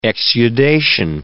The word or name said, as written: exudation